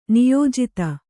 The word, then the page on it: ನಿಯೋಜಿತ: niyōjita